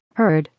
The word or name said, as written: heard